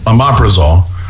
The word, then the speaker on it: omeprazole: Omeprazole? – Yeah, Omeprazole.